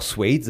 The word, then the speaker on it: suede: Suede, man kann auch Suede sagen.